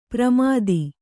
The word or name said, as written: ಪ್ರಮಾಥಿ